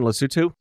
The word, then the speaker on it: Lesotho: Is that the correct pronunciation, Lesotho? – Yes.